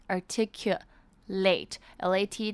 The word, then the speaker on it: articulate: articulate，L-A-T 的 A 呢，则是发 A 的音，指的是清晰吐字。